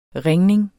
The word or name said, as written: ringning